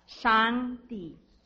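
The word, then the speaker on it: Shangdi (上帝): Shangdi.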